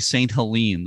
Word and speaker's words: St. Helena: From now on, I'll say St. Helene, the way you pronounce it.